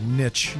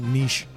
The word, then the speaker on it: niche: And yes, I pronounced that niche, not niche.